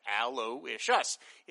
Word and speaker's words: Aloysius: Jay Effingham Bellwether says, the name Aloysius is pronounced Aloysius. It is the Latinized version of names like Louis, Louis, Luis, Luigi, etc.